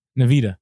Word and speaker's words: Nvidia: Nvidia.